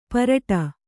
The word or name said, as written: ಪರಟ